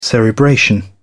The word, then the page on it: cerebration: British English